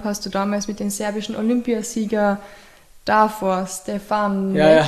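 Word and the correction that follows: Štefanek: falls ich den richtig ausgesprochen habe. – Ja, das ist Stefanik, ja. Genau.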